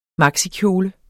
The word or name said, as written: maxikjole